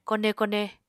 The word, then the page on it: kone kone: Apatani